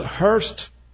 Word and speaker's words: Hearst: Hay una anécdota que dice, y con esto vamos a terminar, cómo William Randolph Hearst, está bien pronunciado, Hearst,